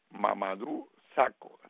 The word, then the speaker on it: Mamadou Sakho: Ni siquiera hay una petición de indemnización a el antiguo jugador del Liverpool, Mamadou Sakho. Se dice así, Pepe, porque tú conoces a Mamadou hace rato. – Sí, sí, sí, claro.